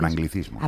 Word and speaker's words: anglicismos: ¿Cómo se dice? Anglojismo. – Con anglicismos. – Ah, perdona. Anglicismos.